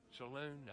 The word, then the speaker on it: Saloon: If you call in here and give me the correct answer, I'll give you lunch, dinner for two for McCoy's Smokehouse and Salon down on Old Pineville Road. What did I say? Did I say it right? Saloon? I said salon. It's a salon. We just spell saloon.